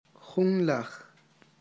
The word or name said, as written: junlaj